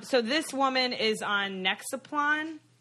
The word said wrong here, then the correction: Nexplanon: Is that how you pronounce it, Shannon? – Yeah, it's what goes in. It's the one that goes under your arm. – I've been on what is that? Nexplanon. What is it? – It's just a hormonal implant.